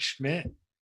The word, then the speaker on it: Schmidt: And LinkedIn, you can just find me at Mark Schmidt, and that Mark is spelled M-A-R-C. Last name, S-C-H-M-I-D-T.